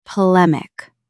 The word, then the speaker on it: polemic: Stress the second syllable. P-lem-ic. Say it aloud. Polemic.